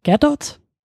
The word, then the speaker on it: Gadot: Gerdot, ja, das hat sie mal jetzt im, äh, in äh, Late Night Show mal erklärt. Äh, ich habe das auch immer so französisch ausgesprochen, aber es wird, das T ist tatsächlich hart.